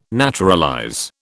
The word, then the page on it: naturalize: British English